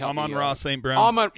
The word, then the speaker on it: Amon-Ra St. Brown: How do you say his name? Brown? Help me. – Amon-Ra St. Brown.